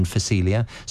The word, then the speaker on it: Phacelia: So the P is silent in Phacelia. So it's Phacelia, Phacelia. And you'll get that in your local garden centre.